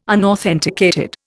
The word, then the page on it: unauthenticated: Indian